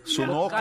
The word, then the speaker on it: Sunoco: É o posto Sunoco Hoffman Car Wash. Pronunciei certo? – Sim. – Não. – Sunoco.